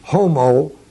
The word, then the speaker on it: homologeo: The word for confess is the word homologeo. H-O-M-O-L-O-G-E, long O. And legeo, the root of that, is the word logos, L-O-G-O-S.